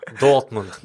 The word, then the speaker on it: Dortmund: Jag älskar ditt uttalande av Dortmund. Dortmund, det är det som ska uttalas.